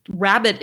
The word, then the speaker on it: Rhabit: for my listeners, Rabbit is spelled RHABIT.